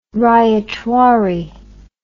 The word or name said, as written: ryotwari